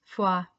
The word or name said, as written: Fua